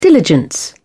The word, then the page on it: diligence: British English